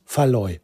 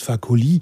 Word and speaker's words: Fakoly: Und nicht Faloy. Faloy ist völlig falsch. Was ich immer gesagt habe, warum auch immer, habe ich ihn immer Faloy genannt. Aber er heißt Fakoly.